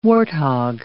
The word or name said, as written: warthog